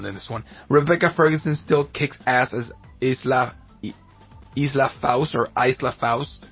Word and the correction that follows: Ilsa Faust: Ilsa Faust is how you pronounce that one.